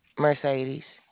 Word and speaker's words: Mercedes: Mercedes. – Okay. – It's spelled like the car.